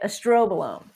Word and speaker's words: estrobolome: I think it's the estrobolome. Yes. So think like strobe light, estrobolome. Yes.